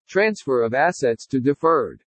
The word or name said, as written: TRANSFER OF ASSETS TO DEFERRED